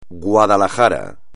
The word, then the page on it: Guadalajara: espagnol